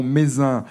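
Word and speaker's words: Mézenc: Donc ce sommet du mont Mézin, ça s'écrit M-E-Z-E-N-C, mais dans la pratique on dit Mézin la plupart du temps.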